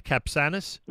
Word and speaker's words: Capçanes: Jürgen Wagner runs Capçanes. Am I pronouncing that correctly? I hope so. Jay, is that right? Capçanes? – That is correct.